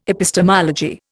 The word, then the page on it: epistemology: Indian